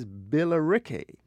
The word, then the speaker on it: Billericay: But the town in England that they borrowed it from is Billericay. So it's the same spelling with a Y at the end.